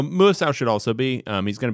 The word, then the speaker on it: Muasau: And then they have linebacker Darius Mausu. Is that how you say it? – Muasau.